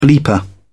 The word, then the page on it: bleeper: British English